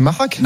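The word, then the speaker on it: Machac: Il est opposé à Thomas Mahar. Je sais pas comment on dit. Il faudrait que j'écoute sur l'application. – Personne ne sait prononcer son nom à lui, c'est fou. Hein. – C'est Mahrak.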